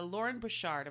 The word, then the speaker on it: Lauren Bouchard: so literal children Lauren Bouchard, am I saying his name right? – Yes.